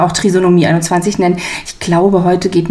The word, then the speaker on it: Trisomie 21: Mir fällt jetzt hier schon auf, dass ich das Wort immer falsch ausspreche. Das heißt nicht Trisomie 21, sondern Trisomie 21.